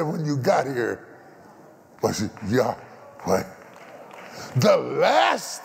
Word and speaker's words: Yahweh: His name, Yahweh, properly pronounced, is the same sound of breathing. Yahweh means you have to inhale, Yahweh. Yahweh. You cannot say it without inhaling and exhaling.